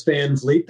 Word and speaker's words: Van Vliet: And I'm at Chris Van Vliet. That's how you pronounce it. VanVliet on all social media and on YouTube.